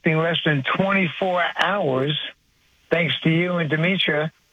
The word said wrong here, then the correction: Dimitri: I think I pronounced that correctly. – Dimitri, yeah. – Close enough. – Dimitri.